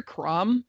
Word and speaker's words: Chrom: And anytime I hear Crom, it's spelled Chrom, but I think of Conan the Barbarian's God of War Crom.